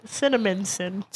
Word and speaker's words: Cinnaminson: It was really good in Cinnaminson, New Jersey. – What? – Cinnaminson. I think that's what it's called. – Cinnamon sticks? – Cinnaminson. – Okay. – First of all, I'm saying it right. Cinnaminson.